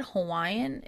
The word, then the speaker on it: Hawaii: if you're saying the place name, Hawaii, you would say the V.